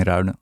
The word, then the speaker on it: Ruinen: Jullie hebben een bed and breakfast in Ruinen, zeg ik het goed? – Ja, Arnhot. Ja, in Ruinen.